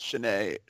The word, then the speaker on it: Sinéad: I think it's pronounced Sinéad.